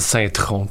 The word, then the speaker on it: Saint-Tron: Saint euh, euh, Truden? En fait, c'est Saint-Tron, tout simplement.